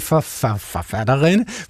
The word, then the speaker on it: forfatterinde: Og jeg udtaler jo ovenkøbet æret i forfatterinde,